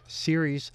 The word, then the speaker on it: Ceres: It's spelled as if it were Sears, like Sears and Roebuck. It's C-E-R-E-S, pronounced Ceres.